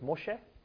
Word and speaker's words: Moshe: Moses is the English pronunciation, and in Hebrew, it's Moshe. Arabic, it's Musa. But